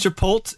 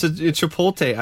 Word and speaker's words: Chipotle: Why would you pronounce the L like a Y? Or not even pronounce the L. That's so weird. – It's Chipotle. It's Chipotle.